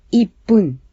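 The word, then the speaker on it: ippun (いっぷん): Los minutos se pronuncian pun cuando el número que viene antes es 1, 3, 6, 8 o 10. De modo que un minuto se dice ippun y tres minutos es sanpun.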